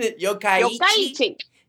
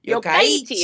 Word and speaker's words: Yokkaichi: Yokkaichi. – Yokkaichi.